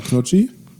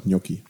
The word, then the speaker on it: Gnocchi: Also die Rottgauer sagen Knocchi, die richtige Rottgauer sagen Knocchi, aber der normale Mensch sagt Gnocchi. Gnocchi.